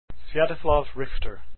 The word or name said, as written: richter